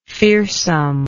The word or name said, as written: fearsomeness